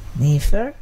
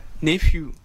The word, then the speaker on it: nephew: Nefer. – Nephew.